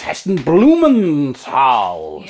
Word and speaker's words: Heston Blumenthal: We had Radio 2 on in the background, and it was Heston Blumenthal.